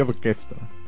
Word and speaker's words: Orchestra: Orchestra di Piazza Vittorio. ¿Lo pronuncio bien? – Más o menos, ¿no? Sería orquesta.